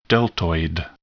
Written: deltoid

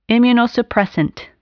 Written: immunosuppressant

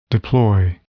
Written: deploy

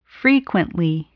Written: frequently

